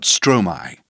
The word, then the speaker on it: Stromae: Turn the page in your magazine to learn more about Stromae. – 1. My plus stro. Stromae is the word maestro with the last syllable first.